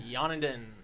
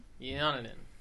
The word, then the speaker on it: Jan and Dean: Yonadin. – Yeah. What, I don't know. What? – Yeah. Yonadin. That's how you pronounce their name. It's not phonetic.